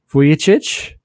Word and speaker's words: Vujicic: Therese called in and she accurately said Vujicic.